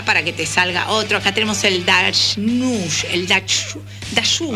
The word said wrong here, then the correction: Dachshund: A ver, ¿cómo se dice? – Dachshund.